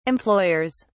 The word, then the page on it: employers: British English